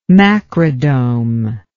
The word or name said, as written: macrodome